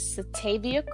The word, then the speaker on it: Satavia: Well, my Facebook name is Satavia Craft, um, I don't know if I need to spell that.